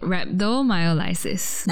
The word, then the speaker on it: rhabdomyolysis: So today we'll be talking about an interesting topic, rhabdomyolysis.